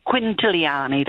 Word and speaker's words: Quintiliani: Now, Una Quintilani, I pronounced that wrong, did I? – Yeah, it's okay, Joe. You're forgiven. It's fine. Okay. It's okay. It's quite a long surname. – No, Quintilian. – Famous in Italy. Quintiliani, darling.